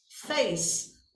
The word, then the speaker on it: face: Então, por exemplo, para o falante de inglês, a palavra face, face, o é é mudo, ou seja, ele é silencioso, a gente não pronuncia, certo? O falante de inglês não pronuncia o é em face.